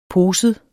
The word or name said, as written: poset